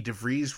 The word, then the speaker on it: DeVries: Thank you once again to our guest Dave Foots Footman, as well as Eddie Schmidt, Vicky DeVries Rhymes with Cheese,